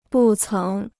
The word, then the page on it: 不曾: bù céng